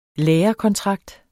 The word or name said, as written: lærekontrakt